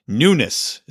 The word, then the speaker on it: Nunes: Look, I looked up how to say it. Nunes is how it's said.